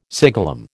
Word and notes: siglum: American English

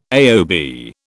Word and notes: AOB: British English

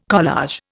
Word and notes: collage: Indian

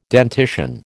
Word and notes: dentition: American English

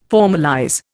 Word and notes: formalize: Indian

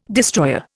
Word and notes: destroyer: Indian

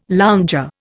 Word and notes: lounger: Indian

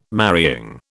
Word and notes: marrying: British English

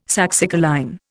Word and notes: saxicoline: Indian

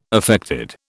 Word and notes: affected: British English

